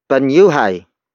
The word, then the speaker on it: Banyuhay: This lesson is brought to you by Banyuhay. Banyuhay. Banyuhay. Banyuhay is spelled as B-A-N-Y-U-H-A-Y.